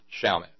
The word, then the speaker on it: Chalmette: Am I saying that right? Chalmette? – Chalmette.